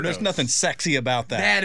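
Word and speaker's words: Sachse: And this lady calls and she's like, excuse me. It's sexy.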